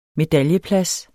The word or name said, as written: medaljeplads